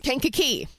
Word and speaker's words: Kankakee: Kankakee.